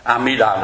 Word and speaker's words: A-mi-đà: Giống như A-di-đà, mình đọc là A-di-đà mình đọc sai, A-mi-đà là nó đúng,